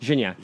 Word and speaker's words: Gignac: How am I supposed to pronounce that? – Gignac. – Gignac? – Oh, Gignac. I got it's French.